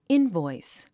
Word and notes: invoice: ínvɔis; インボイス

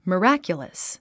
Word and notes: miraculous: mərǽkjələs; マラキュラス

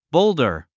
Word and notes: boulder: bóuldə r; ボォウダァ

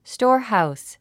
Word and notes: storehouse: stɔ́ːrhàus; ストアハウス

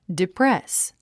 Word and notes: depress: diprés; ディプレス

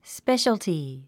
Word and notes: specialty: spéʃəlti; スペシャルティ

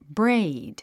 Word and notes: braid: bréid; ブレェイド

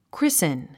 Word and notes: christen: krísn; クリスン

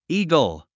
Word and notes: eagle: íːgl; イーグル

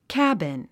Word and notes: cabin: kǽbin; キャビン

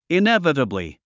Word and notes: inevitably: inévətəbli; イナァビタブリィ

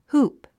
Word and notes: hoop: húːp; フープ

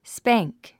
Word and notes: spank: spǽŋk; スパァンク

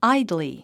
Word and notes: idly: áidli; アイドリィ

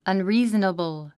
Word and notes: unreasonable: ʌnríːzənəbl; アンリーズナブル

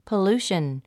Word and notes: pollution: pəlúːʃən; ポルーション

